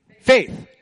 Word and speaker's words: faith: By prayer, by, rhymes with eighth. Faith, right? You received Christ by faith.